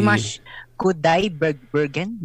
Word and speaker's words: Dimash Kudaibergen: D- Dimash Kudai Bergen.